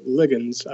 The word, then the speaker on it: ligands: So the concept is these isoflavones are what are called ligands, or as some would pronounce it, ligands, I've heard. Probably some of your British friends might.